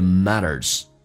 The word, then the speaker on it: matters: If it hurts you, it means it matters. It 的 T 去掉，略音。 Mean 后面加 s 叫 means， z 加 it 有一个零叫 means，而且 t 也不发音。 It means it matters. Mean 长元音，嘴角拉到极限。 Matters t 在美式发音当中要被浊化，两个元音中间所加的辅音要被浊化。